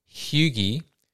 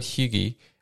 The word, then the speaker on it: hygge: The other part, it says, in brief, hygge. Is that how you pronounce it? Hygge. – Close enough.